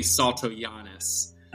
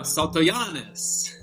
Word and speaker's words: Saltojanes: But I learned recently that it is correctly Saltojanes. – Okay. – So I'm now Saltojanes.